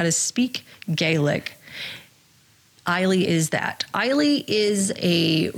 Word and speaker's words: Eilidh: E-I-L-I-D-H. – Oh, no, I can't. – I looked it up. And you will not believe how to pronounce it. – Oh, tell me. Please do tell. – Eile. – Yeah, see? Yes. Eile. – I love it. Why? – Yeah. Every Irish name is the cutest name. It's spelled not with letters. Not phonetically. It's the opposite. – Why is the D and the H completely silent? – Yeah.